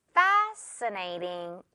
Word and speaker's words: fascinating: Fascinating. 여기에도 지금 SC 붙어 있지만 S 발음만 해주면 돼요.